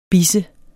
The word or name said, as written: bis